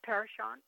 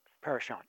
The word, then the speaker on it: Parashant: Parashant. – Parashant, yes, okay. P-A-R-A-S-H-A-N-T. – S-H-A-U-N-T, I think.